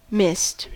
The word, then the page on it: mist: American English